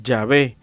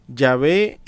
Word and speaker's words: Yahvé: Me permito comenzar con Yahvé. Yahvé es la pronunciación más correcta del hebreo original de las cuatro consonantes que formaban el nombre de Dios. Viene de una forma del verbo Javá, H-A-B-A-H,